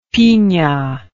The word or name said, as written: pina